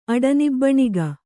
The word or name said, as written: ಅಡನಿಬ್ಬಣಿಗ